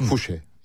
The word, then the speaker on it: Fouché: Fouché. Yani Fransızlar Fouché diye doğrudan telaffuz ediyor.